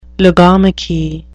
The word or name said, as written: logomachy